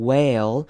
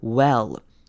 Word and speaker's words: well: well 呢，短母音 e 的声音，很多人会念成 well，那真的那是不对的。中间的 e 会念成 a a a 的声音 well。 Well, my internet's not working.